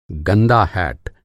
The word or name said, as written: हैट